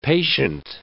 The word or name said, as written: patient